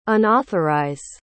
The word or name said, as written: unauthorize